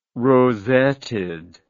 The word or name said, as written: rosetted